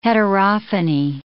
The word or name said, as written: heterophony